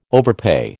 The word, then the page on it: overpay: American English